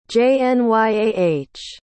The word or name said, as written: jnyah